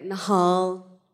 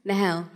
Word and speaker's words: Nahel: I get Nahel. – How do you say it? – Nahel.